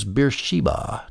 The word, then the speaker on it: Beersheba: Now in the New American Standard Version, the Sheba, Isaac called it Sheba, is spelled S-H-I-B-A-H in the English, and the Sheba in Beersheba is S-H-E-B-A, so it's spelled differently, but it's pronounced the same.